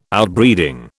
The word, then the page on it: Outbreeding: British English